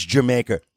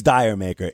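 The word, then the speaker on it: D'yer Mak'er: First of all, I know right away some music fans are going to roll their eyes and say it's Jamaica. No, it's not. It's Dire Maker. It's spelled D-Y-E-R. Now, it's supposed to be a tribute to reggae music. The term Dire Maker, if used with a West Indies accent, is supposed to sound like Jamaica, like did you make her?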